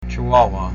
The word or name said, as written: Chihuahua